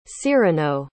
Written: cyrano

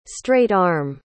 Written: straight-arm

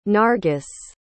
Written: nargis

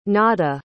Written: nadda

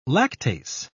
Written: lactase

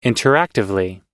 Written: interactively

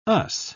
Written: us

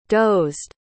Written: dozed

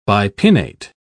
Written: bipinnate

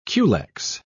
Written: culex

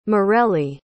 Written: mareli

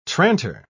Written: tranter

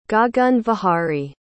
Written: Gaganvihari

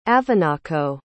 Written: avonaco

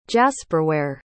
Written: jasperware

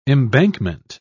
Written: embankment

